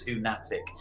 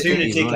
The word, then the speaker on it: Hunatic: People always just think it was Hunatic, as in fanatic. – I did. – No, it's Hunatic, as in lunatic.